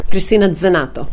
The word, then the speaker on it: Christina Zanetto: Did I say your name right, Christina? – Christina Zanetto. – Zanetto. Of course. There you go. It's more Italian, right? – Yes, I there am originally from Italy.